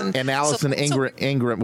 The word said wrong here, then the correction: Arngrim: How do you say her name? Ingram. – Allison Arngrim. – That's right.